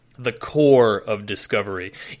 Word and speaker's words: Corps: Even though every motherfucker in that game refers to it as the Corpse of Discovery, because, you know, if you spell core, like the Army Corps is spelled C-O-R-P-S, right? But you don't pronounce the fucking P because you're not an asshole.